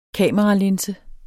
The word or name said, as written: KAMERALINSE